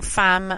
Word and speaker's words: femme: All the Rage, it's called, and they are a fierce femme fronted, I'm saying femme as in the French pronunciation, femme fronted alt vibes.